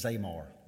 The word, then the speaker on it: zamar: A second word that's often translated as praise in the Old Testament is zamar, Z-A-M-A-R.